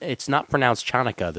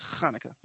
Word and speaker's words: Hanukkah: It's not pronounced Chanukah. The C is silent in Hanukkah. – It's Hanukkah.